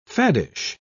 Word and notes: faddish: British English

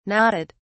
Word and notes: nodded: British English

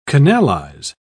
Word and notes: canalise: British English

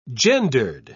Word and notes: gendered: British English

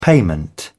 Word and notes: payment: British English

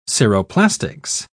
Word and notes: ceroplastics: British English

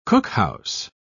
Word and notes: cookhouse: British English

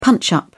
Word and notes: punch-up: British English

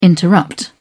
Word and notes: interrupt: British English

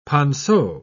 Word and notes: ponceau: British English

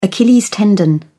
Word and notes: achilles tendon: British English

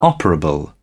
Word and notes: operable: British English

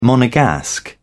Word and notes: monegasque: British English

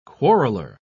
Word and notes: quarreller: British English

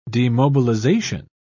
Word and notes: demobilisation: British English

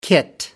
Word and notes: kit: American English

